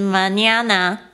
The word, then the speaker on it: mañana: Hasta mañana. – Hasta mañana。Hasta mañana。那我们现在就是要好好的来学习这两个单词到底是什么意思呢？首先呢，我要讲一下，就是 mañana mañana 这个字呢，就是明天 tomorrow mañana。呃，示范一次好了。 – Mañana. – mañana，那个 n 的那个声音啊，上面有一个这样子曲线的，那个是怎么念？How do you pronounce that n？ – Yeah, it's for us.